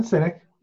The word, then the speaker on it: Sinek: From a work perspective, you know, we always talk about we kind of did this Simon Sinek. I think I'm saying his name wrong. – Simon Sinek. – Yeah. Okay. Yeah.